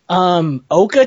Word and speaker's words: Oka: Oka cheese? Is that how it's pronounced? – Oh, God, that is... Yes, that's a thing. – Wait, how's that spelled? – O-K-A.